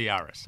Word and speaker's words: Urus: Real, it's the Urus.